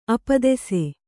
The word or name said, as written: ಅಪದೆಸೆ